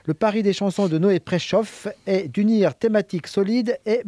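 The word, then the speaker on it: Noé Preszow: Noé Préchoff, ça s'écrit à P-R-E-S-Z-O-W.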